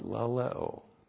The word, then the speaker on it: laleo: And then the basic Greek verb for communication of the Word of God, laleo. L-A-L-E long O.